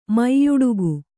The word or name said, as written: ಮೆಯ್ಯುಡುಗು